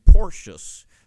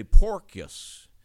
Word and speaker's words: Porcius: And by the way, in verse 27 of the previous chapter, I pronounced the name Porcius. That's how we would say it in English. But in Greek, it's actually a K, a kappa, rather than the C. And you would say Porcius.